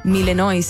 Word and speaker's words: Malinois: Pastor de Malinois. Milenois se escribe. – Mira, yo le hubiese dicho milanesa.